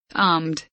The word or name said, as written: armed